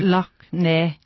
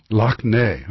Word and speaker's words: Loch Ness: Now, you notice I was reading this, and I stayed studiously away from the name of the lake, the shores of, how do you say? – Loch Ness. – Loch Ness.